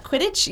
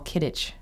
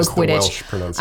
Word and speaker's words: Quidditch: Is that the right way to pronounce that? – Quidditch? Yes. Yeah. I feel good about that one. I feel good about that one. I've read, yeah. – I've heard people go all Kidditch, say that that's how it's pronounced. Okay. I would go Quidditch. I don't know. I think it's like nerds being like, no, it's this. – I'm comfortable with Quidditch.